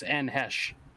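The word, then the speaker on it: Anne Heche: Is that how you pronounce it, Anne Hesch or Anne Hesch? – Yeah. Every time I heard that on, you know, Leno or Letterman, it was Anne Hesch.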